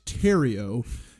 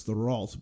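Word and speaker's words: Thériault: Rock Terrio, it's spelled T-H-E-R-I-A-U-L-T, which I would think is the Ralt, but in thing is, when you get to poutine country, they start saying words real fucking weird.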